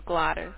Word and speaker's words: Gladys: My name is Gladys. – Can you spell it for me? – That's G-L-A-D-Y-S as a Sam.